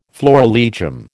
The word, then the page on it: florilegium: American English